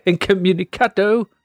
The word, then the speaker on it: Incommunicado: You have to say it like that. That's how you got to say it. Incommunicado.